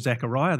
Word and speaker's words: Zachariah: So I'm going to call him Zachariah. That's the English way to pronounce it.